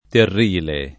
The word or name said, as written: Terrile